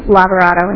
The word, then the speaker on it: Lavorato: Again, my name is Cindy Lavarato, and that's spelled L-A-V-O-R-A-T-O.